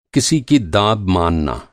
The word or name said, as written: दाब